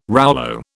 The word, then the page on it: rouleau: British English